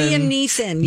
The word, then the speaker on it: Neeson: Yeah, Liam Nelson. – Liam Neeson. Yes. – Neeson. Oh gosh, I said that wrong.